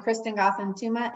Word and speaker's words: Kristen Gotham Touma: I'm Kristen Gotham Touma and I am a badass.